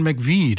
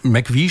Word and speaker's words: McVie: Und McVie schreibt sich MC-Wie, aber das spricht man ja so nicht aus, sondern deswegen wurde es einfach Mac, M-A-C, geschrieben.